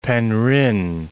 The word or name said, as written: Penrhyn